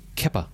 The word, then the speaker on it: Kepa: If you see the letters K-E-P-A, you're going to say Kepa.